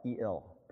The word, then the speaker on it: El: And another interesting thing about the Hebrew, right before the word trust, is a little word, right before Jehovah, is the word El, E-L, pronounced El.